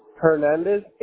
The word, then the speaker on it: Hernandez: Now, your first name, you spell it P-A-U-L-I-N-O, Paulino? – Yes. – Okay, and what's your last name? – Hernandez, H-E-R-N-A-N-D-E-Z.